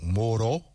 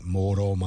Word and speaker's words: 뭐로: You can say 뭐로, but in most cases, you have to pronounce 리을 as R, similar to R. – If there's just a single one, it's just like an R, like a right. – rolled R.